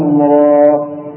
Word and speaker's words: را: اكتب امامك را الف لا توجد في الاخر همزه ليس الخطا و هذا خطا، وجود همزه في الاخر خطا، هي و فقط راء بعدها الف.